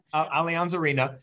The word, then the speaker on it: Allianz Arena: Allianz Arena.